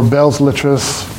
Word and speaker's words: Belles Lettres: What book on philosophy or religion or psychology or Belles Lettres?